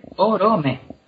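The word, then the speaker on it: Oromë: Tenemos el nombre típico en el que la segunda sílaba es débil, que es Orome. Orome. ¿Está? Oro no termina en consonante, pues es débil. Orome.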